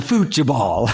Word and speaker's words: futebol: And he tells you that the way that they say football in Portuguese is a futebol.